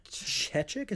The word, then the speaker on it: Chechik: Chechik, is that how you pronounce his name? Yeah,